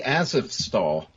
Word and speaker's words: Azovstal: Oh, I'd say Azovstal.